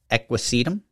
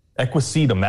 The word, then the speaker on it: Equisetum: Um, and uh, the ri- little writing off uh, iNaturalist, and the botanical name for it is Equistum hyamale. Um, I think it's pronouncing that wrong. – equisetum. Equisetum. That makes That's a lot more sense.